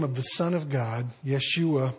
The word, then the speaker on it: Yeshua: Well, Jesus' name, the Americanized version is Jesus. It's actually Yeshua. That would be the American pronunciation of the Hebrew name.